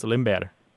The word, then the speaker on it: Limber: And the word is L-I-M-B-E-R. – Okay, very good. It looks like limber, but it's pronounced limber.